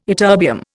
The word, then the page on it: ytterbium: Indian